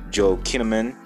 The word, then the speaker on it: Joel Kinnaman: Joel Kinnaman, that's how you say his name.